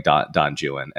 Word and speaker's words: Don Juan: I think like Don Juan, and yes, that is how it's pronounced because British people refuse to adopt the pronunciation of any other country.